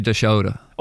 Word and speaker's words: Dashoda: I would say Dashoda. Okay, Dashoda. Okay. But I can, you can be your interpretation as well, you know.